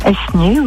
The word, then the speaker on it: Essaigné: Essaigné, oui. – Ah, vous ne prononcez pas le E du milieu, vous? – Bon, on dit Essaigné.